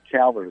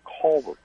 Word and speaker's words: Calvert: Oh, well, let me say, number one, that story from, well, not Calvert, it's Calvert County. – Yeah, I know. I mispronounced it.